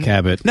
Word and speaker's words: Cabot: You got some fine kabat cheese. – Yeah, Mike was eating kabat cheese. Have you ever heard of kabat cheese? – Apparently, it's pronounced Cabot. – No, because it's Cabot.